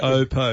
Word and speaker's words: Oppo: Oppo, okay. They say. So, yeah. Well, it's OPPO. – That's how the companies. That's how they pronounce their own name.